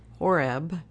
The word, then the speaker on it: Horeb: And he led the flock to the back of the desert and came to Horeb, the mountain of God. Is that how you say it? H-O-R-E-B.